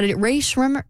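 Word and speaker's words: Rae Sremmurd: Yeah, Ray Shrimmer. – Yeah, go with that. That's better. – It's spelled S-R-E-M-M-U-R-D, Ray Shrimmer.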